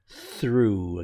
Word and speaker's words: through: Okay, so as an example of direct speech, and if we're looking for some pronunciation, that word through, again, one of those TH sounds, through.